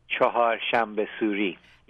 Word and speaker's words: Chahar Shanbah Suri: And I just learned over the weekend that all of my Persian friends have been celebrating let's see if I can try it, Chahar Shanbah Suri. How do I say that? – Chahar Shanbah Suri, yes.